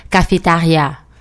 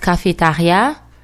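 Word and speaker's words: cafétéria: Cafeteria, it's also the same thing, but the pronunciation is different. In French, it's cafetaria, but it's spelled the same way. Cafeteria, it's cafetaria.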